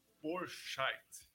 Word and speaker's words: Bourscheidt: Borchait.